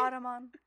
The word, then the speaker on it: Audubon: All right, so writing, according to Audubon, I feel like I'm saying Ottoman, but I Ottoman. Know this is how you say it. – Audubon.